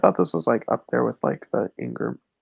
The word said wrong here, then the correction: Ingmar: I said his name wrong already. – Ingmar Bergman.